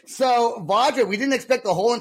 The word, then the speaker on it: Vajra: We are with the band Vajra, right? That's how you say it. – That's it. You did it.